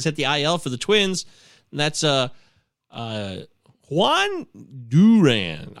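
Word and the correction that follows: Jhoan Duran: I looked this up. It's Johan Duran. Johan Duran, I believe, is how I looked down the pronunciation.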